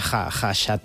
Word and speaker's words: Khachaturian: Aram, se escribe K-H-K-H-A, pero se pronuncia Khachaturian.